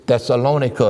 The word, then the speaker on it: Thessalonica: I like the way they pronounce it over there. They got the airport. They call the place Thessaloniki. – Okay. – And this is Thessalonica, as some people would say.